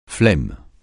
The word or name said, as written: flemme